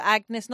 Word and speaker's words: Agnis: But Agnes, not like the name, but A-G-N-I-S.